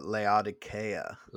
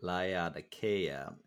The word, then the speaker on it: Laodicea: Well, the locals of the Turkish land call it Laodicea. – Laodicea. And that you would probably know it as Laodicea or Laodicea.